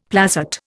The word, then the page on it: placet: Indian